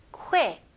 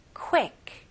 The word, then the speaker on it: quick: 근데 여기 모음 소리는 E 발음이 아니에요. E, I 발음으로 해주셔야 돼요. 그래서 quick보다는 quick, E. quick, quick, okay? 그래서 너무 빠르게 짧게 하시는 것보다 모음 소리를 조금 길게 늘려주시면 더 좋아요.